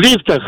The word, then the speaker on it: лифтах: Абсолютно во всех словарях зафиксирован единственно верный вариант. И он с ударением на первый слог, поэтому правильно говорить еще раз как? – В лифтах.